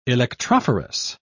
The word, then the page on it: electrophorus: British English